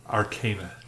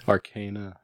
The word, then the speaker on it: Arcana: Only I pronounce it. Arcana. – Arcana. Yeah. – Got us. Arcana. – I think we'll go with your pronunciation.